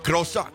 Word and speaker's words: croissant: Croissant. That's the French pronunciation.